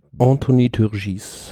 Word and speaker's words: Anthony Turgis: Anthony Turgis.